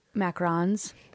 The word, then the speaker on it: macarons: And seeing the fun different things like uh, like macaroons and like cake pops were so hot for so long. – How do you say and, that like, word? – mac- macarons. There you go.